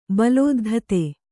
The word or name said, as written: ಬಲೋದ್ಧತೆ